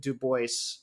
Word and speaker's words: Du Bois: And he very pointedly pronounced it Du Bois as a way of sort of claiming his Americanness and his American blackness, and not alluding to maybe the European aristocratic feel that it might give.